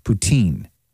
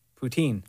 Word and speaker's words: poutine: Most people say the emphasis on the teen. Poutine. – Poutine.